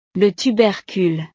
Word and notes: le tubercule: frances

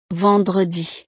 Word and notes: vendredi: frances

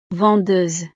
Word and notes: vendeuse: frances

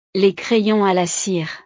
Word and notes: les crayons a la cire: frances

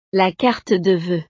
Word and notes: la carte de voeux: frances

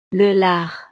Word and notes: le lard: frances

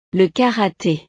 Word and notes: le karate: frances

